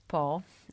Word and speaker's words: Pau: to właśnie znajdziecie miejscowość Po, e, pisze się Pau, e, w której znajduje się jeden z lepszych uniwersytetów na południu Francji.